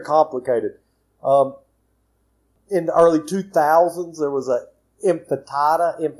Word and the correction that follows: Intifada: or something like that. I can't pronounce. – Intifada.